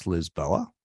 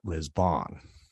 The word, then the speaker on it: Lisboa: I think it's correctly pronounced Lisboa, but we pronounce it Lisbon.